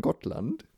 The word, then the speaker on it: Gotland: Auf jeden Fall fahren wir nach Gotland oder wie die Schweden sagen, Gottland. – Mhm. Komischerweise wird das kurz ausgesprochen, aber genau, da fahren wir hin.